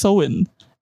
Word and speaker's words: Samhain: Apparently, it's spelled Samhain but it's pronounced okay, okay, okay. – But it's according to Google Translate. But it's pronounced Soin, and it's apparently they used to celebrate at this time of the year.